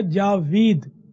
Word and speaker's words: جاوید: اصل اس کا تلفظ تو ہے جاوید، جاوید من ہمیشہ رہنے والا،